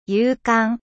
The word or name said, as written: courageux